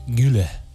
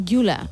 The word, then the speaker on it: Güler: Creo que Arda es Arda Güler. – Ah, Güler.